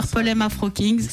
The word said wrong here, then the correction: Purple M Afro Kings: C'est vrai que je le prononce pas bien, Purple Lem. – Purple pour le violet. – Purple. Purple M, c'est pas évident à prononcer. – Oui. – Je me disais à l'antenne la semaine dernière comme quoi c'était pas la bonne prononciation et que vous-même vous allez le prononcer comme il faut. – On te pardonne, Arnold. – Ah bah, t'es au top. – C'est Purple M Afro Kings.